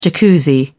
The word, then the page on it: Jacuzzi: British English